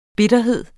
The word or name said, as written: bitterhed